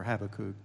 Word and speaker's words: Habakkuk: This is fleshed out a little bit more, at least the same general parallel with Habakkuk. Or Habakkuk, depending on how you want to pronounce it.